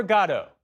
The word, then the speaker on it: Godot: Waiting for Godot, yes, that's how it's pronounced, mixes high despair and low comedy to raise big questions about, well, the meaning of life.